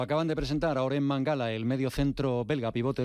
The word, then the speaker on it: Mangala: Él ha comentado que se pronuncia Mangala.